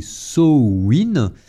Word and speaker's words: Samhain: également connu sous le nom de Samhain, prononcé Samhain, qui est une fête traditionnelle celte qui marque la fin de l'année.